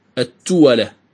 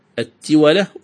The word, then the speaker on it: التولة: والتولة تلفظ بكسر التاء وفتح الواو، التولة، وتلفظ أيضا بضم التاء وفتح الواو، التولة، التولة والتولة. يقول الخليل في كتابه العين: التولة ويقال التولة.